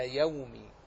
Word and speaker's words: يوم: نحرص على النطق هكذا يومي بفتح الياء وإسكان الواو، يوم الدين.